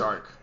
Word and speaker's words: Chark: Was that how do you say it? Chark. Chark. Yep.